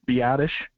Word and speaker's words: Biadasz: And we got, Biadasz, is that how you pronounce his name? – Biadasz. – The center we got right now. Biadasz.